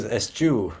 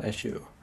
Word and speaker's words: eschew: I had always pronounced that as a shoe, and now I Googled it, and it looks like it is a hard CH. I'm just going to listen to what Google tells me this sounds like. Oh, yeah, that's a hard CH. Wow, okay. Well, thank you for not making me look like a fool when I pronounce that. I'm sure I have looked like a fool in the past, but it is eschew, folks, not eschew.